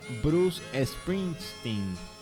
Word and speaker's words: Bruce Springsteen: Pues nos vamos a ir con una canción que le encanta a Jesús Morales, de Bruce Springsteen.